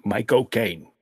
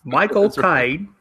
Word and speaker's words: Michael Caine: You got to say it right. You have to sound like you're saying my cocaine. Michael Caine. – Michael Caine.